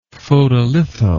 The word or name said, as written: photolitho